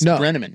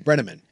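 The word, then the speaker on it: Brennaman: Tom Brenneman? – Yeah, Brenneman. – Yeah. – What did I say? Is it Brennan? – You said Brennan. – Brenneman. – But that's not right. It's Brenneman. – Brenneman.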